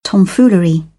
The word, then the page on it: tomfoolery: British English